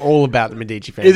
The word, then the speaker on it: Medici: I know it's pronounced Medici.